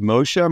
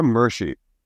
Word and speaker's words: Moshe: Yo Mama's so wrong, she pronounces Moshe Mershi.